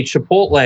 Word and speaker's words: Chipotle: If you send Spencer a Chipotle, am I pronouncing it right? – You got it.